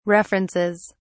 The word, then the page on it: references: British English